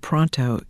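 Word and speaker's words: Paranteau: Paris Parento, do I have that name right? – Well, you do, but it would be called Pronto. – Pronto. – It would be pronounced Pronto, but it's Parento.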